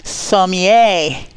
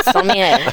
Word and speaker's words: sommelier: Sommier. – Yes, how'd she do? Good job. – I've very good. – Good job. Sommelier.